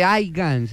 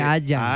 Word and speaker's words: haya: No, no se dice aigan, se dice haya.